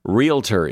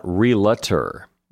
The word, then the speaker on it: realtor: Realtor is another one. It's not realtor. There are not three syllables in that word. It's just realtor.